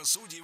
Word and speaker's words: судей: Судей. И это правильно. Что интересно, раньше нормой было ударение на окончании «судей». Потом была в ходу двойная норма «судей» и «судей». А сейчас вариант «судей» вытеснил другой вариант и в гордом одиночестве присутствует в словарях. Поэтому только «судей».